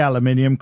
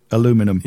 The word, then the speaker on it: aluminium: I say aluminium because I'm British Americans would call it aluminum. – Yeah, well, they don't pr- they don't spell it properly. They remove an i.